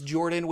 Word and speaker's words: Jorphdan: My name is Jordan with a silent PH in the middle, and I am joined always by my wonderful co-host, Sir Lucian, over at Sir Lucian Gaming.